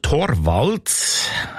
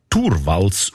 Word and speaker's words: Torvalds: Der Linus Torvalds, eigentlich Torvalds ausgesprochen, dazumal Informatikstudent Helsinki, er hat die wertvollste Software von der Welt namens Linux einfach verschenkt.